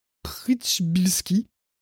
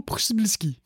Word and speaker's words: Przybylski: C'est un mec qui s'appelle Andrew Przybylski. Je le prononcerai qu'une seule fois, n'insistez pas. Donc le mec n'est pas... entendu, ça n'a pas enregistré, tu peux le redire? – Andrew Przybylski, voilà, je pense. C'est P-R-Z-Y, je ne sais pas trop comment ça se dit.